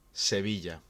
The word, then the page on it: Seville: Spanish